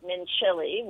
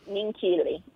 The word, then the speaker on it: Minchilli: Well, you were pronouncing it Mincilli, which is how it's spelled, M-I-N-C-H-I-L-L-I. – Right. – But the C-H is actually hard, so it's Mincilli.